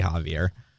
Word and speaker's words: Xavier: I think that is that's how it's spelled. – Xavier. – But it's pronounced. – Yeah. – I've heard it. People say Javier.